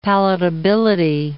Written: palatability

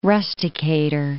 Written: rusticator

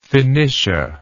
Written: Phenicia

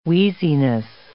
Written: wheeziness

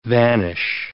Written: vanish

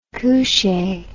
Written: couchee

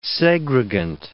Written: segregant

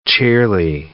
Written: cheerly

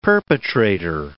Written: perpetrator

